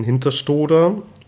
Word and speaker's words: Hinterstoder: Wir haben jetzt in Hinterstoder, richtig ausgesprochen? – Mhm, genau.